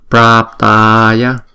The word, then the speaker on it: Praptaya: Chữ Bra chúng ta đọc là hai nhịp, xong rồi chúng ta mới đóng chữ đó lại bằng chữ P phở nè. Praptaya, Praptaya. Đừng có đọc là Praptaya, Prap là không đúng, là Prap, Praptaya, Praptaya.